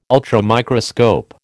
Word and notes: ultramicroscope: American English

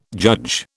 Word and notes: judge: British English

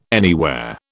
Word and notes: anywhere: British English

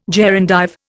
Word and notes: gerundive: Indian

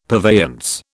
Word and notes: purveyance: British English

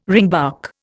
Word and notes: ringbark: Indian

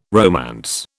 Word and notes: romance: British English

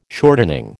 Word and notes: shortening: American English

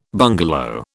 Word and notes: bungalow: British English

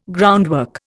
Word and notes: groundwork: Indian

